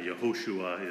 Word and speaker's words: Yehoshua: You know, and the Hebrew is, yeah, Yehoshua is the way they would have pronounced it originally, and then it variously gets kind of corrupted as the language moves forward.